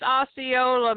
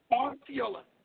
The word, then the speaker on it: Osceola: I think it's pronounced Osceola. – Osceola. – Yeah.